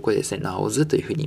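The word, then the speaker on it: 脑子: えー、脳という字にですね、子供という字を書きまして、えー、発音はですね、えー、脳という字がナオというふうに発音します。えー、というのは子供の子という意味で、えー、ナオズというふうに言います。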